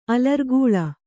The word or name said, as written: ಅಲರ್ಗೋಲ